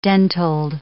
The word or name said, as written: dentiled